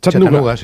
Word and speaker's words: Chattanooga: ¿Se pronuncia así en Chattanooga? – Chattanooga. Sí. Chattanooga City.